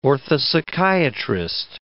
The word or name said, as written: orthopsychiatrist